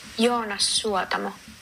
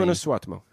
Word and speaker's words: Joonas Suotamo: Joonas Suotamo.